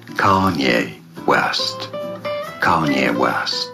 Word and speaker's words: Kanye: Kanye. – Kanye, finde ich, das sagst du super. – Ich glaube, die Betonung ist auf der ersten Silbe.